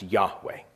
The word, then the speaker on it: Yahweh: We translate them Y-H-W-H. It's often been pronounced Yahweh.